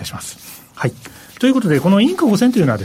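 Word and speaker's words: Inc. 5000: 実はですね先週ちょっと私事でお休みいただきましたのでですね先々週ちょっと話題にしていたアメリカの急成長企業ランキングインク 5000、 INC5000 と書いてインク 5000 と読むんですけど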